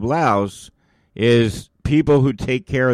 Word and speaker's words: Laos: The official language in the country of Laos is Lao, L-A-O. And if you want to do some extra research about the country of Laos, it's pronounced Laos, but it's spelled L-A-O-S.